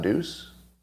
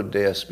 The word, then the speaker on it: Deus: D D-E-U-S is pronounced Deus, not deuce.